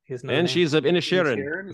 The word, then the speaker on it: Banshees of Inisherin: I did watch, let's see, since we're talking about some of these Oscar ones, I did watch the Banshees of, how do you say it? – Banshees of Inisherin. – Is that right? – Yeah. Mm-hmm. Yeah.